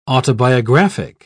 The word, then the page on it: autobiographic: British English